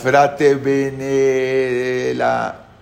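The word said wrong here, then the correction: Fatebenefratelli: Fate bene fratelli.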